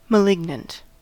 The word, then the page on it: malignant: American English